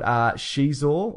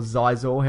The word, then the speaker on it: Xizor: And a guy called Xizor or Xizor, however you want to pronounce it, X I Z O R.